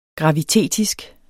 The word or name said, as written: gravitetisk